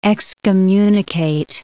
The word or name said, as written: excommunicate